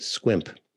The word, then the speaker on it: SQMP: And the initials for that are SQMP, and you pronounce it SQIMP.